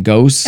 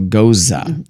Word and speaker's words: Gose: No, so when I read it, it's like... – G-O-S-E. – Yeah, G-O-S-E, Ghost? – But it's like Rosa. Rosa? So Goza. – That's still, what Google said, so it must be right, babe. – It's Google told me. – It says it rhymes with Rosa.